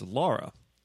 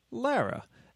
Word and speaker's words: Lara: But a lot of the time, people mispronounce my wife's name as Laura. It is, in fact, Lara. And when they ask, well, Lara, like they go, Lara? I'm like, yes, like Lara's name from Dr. Zhivago.